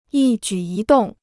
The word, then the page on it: 一举一动: yī jǔ yī dòng